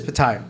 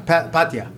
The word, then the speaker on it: Pattaya: So he says it's Pattaya. Pattaya.